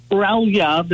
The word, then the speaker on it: Grelja: My last name is Grelja. The J sounds like a Y, like Bjorn.